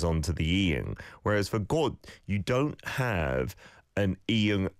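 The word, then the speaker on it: kot (꽃): And Siska says, "Kot." The pronunciation sounds like kot in my ear anyway.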